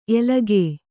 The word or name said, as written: ಎಲಗೇ